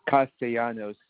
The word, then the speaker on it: Castellanos: A-N-T-H-O-N-Y. And my last name is Castellanos. It is spelled C-A-S-T-E-L-L-A-N-O-S.